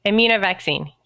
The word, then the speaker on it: Immunovaccine: Immunovaccine, did I? – Immunovaccine, yes.